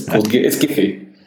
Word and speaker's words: Giphy: Although I'm going to have to pronounce you on correct one of the pronunciation. It's Giphy.